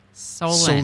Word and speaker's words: Solon: But if it's not Black River Falls, it's this place up north and a little west called Solon Springs. – Uh-huh. – She says, no, it's pronounced like Solin. – Solin. – Solin Springs. It's it's S-O-L-O-N.